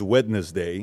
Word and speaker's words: Wednesday: at 12, 13 years old, I can't pronounce the word Wednesday, is Wednesday. Mm-hmm. I would always say the D.